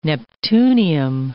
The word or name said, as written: neptunium